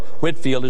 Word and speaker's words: Whitefield: And shortly after his arrival here, his good friend George Whitefield, or Whitfield as some would perhaps pronounce it, came and joined him and started an orphanage near Savannah, Georgia.